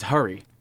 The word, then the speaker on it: Hari: I also just found out I've been mispronouncing his name for about a decade. Yeah. It's Hari. I've been saying Hari. So if you ever meet Hari Kondabolu, that's how you say it.